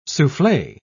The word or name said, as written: souffle